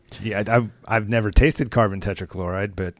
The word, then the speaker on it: tetrachloride: Carbon tetrachloride.